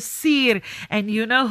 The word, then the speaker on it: Siir: Well, it is spelled S-I-I-R and pronounced Seer.